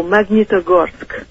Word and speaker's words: Magnitogorsk: Oh, Magnitogorsk.